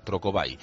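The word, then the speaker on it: Trocobuy: Trocobay se escribe, bueno, como suena, pero la última parte de la palabra buy es el verbo comprar, en inglés, B-U-Y, Trocobay.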